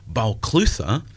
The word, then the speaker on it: Balclutha: And um, at the bottom of it, you've got a town that you incorrectly pronounce Balclutha, which in Gaelic would be Balaclua.